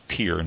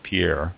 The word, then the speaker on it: Pierre: And, by the way, we discovered that it is pronounced Pier, not Pierre, by one of our wineries we talked to.